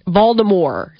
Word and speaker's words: Voldemort: Voldemort. – So that T is a silent T.